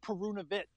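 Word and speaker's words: Perunović: And he said it's Perunovic, like okay. – Cheese or cheddar or chunky with a C-H.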